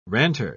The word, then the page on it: ranter: British English